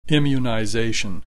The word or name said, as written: immunization